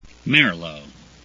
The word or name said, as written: merlot